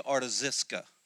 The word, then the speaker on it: Artaxerxes: And his name is actually Artaxerxes, is actually how you pronounce his name.